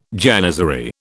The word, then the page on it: janizary: British English